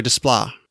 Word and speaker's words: Desplat: Yeah, by Alexandre Desplat. You heard of that name before several times. – Does it have a silent T on the end? – Yes.